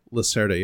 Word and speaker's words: Lacerda: Yeah, the only thing I know about Lacerda, am I saying that right? – Lacerda, yeah.